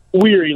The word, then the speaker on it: Wehry: Weary, like tired.